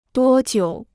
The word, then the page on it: 多久: duō jiǔ